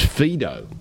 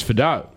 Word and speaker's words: Fidow: I was told initially it was Fido. – Okay. – But no, I think you're right. It's Fidow.